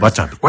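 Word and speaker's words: 和茶美: お茶に美しいと書いて、わさびという。 – わちゃび。 – わちゃびですね、そうですね。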